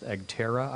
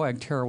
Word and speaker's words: EGTRRA: The Roth 401k was created by the Economic Growth and Tax Reform Reconciliation Act EGTRRA, which is pronounced EGTERRA, I believe.